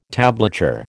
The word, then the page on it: tablature: American English